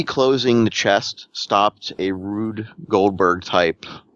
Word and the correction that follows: Rube: A rude? He's rude? – Rube. – Okay. – Rube Goldberg.